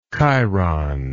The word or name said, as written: Cheiron